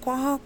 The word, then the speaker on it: quahog: Quahogs? Do you pronounce it quahog? – I say quahog.